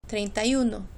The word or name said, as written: treinta y uno